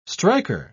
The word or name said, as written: striker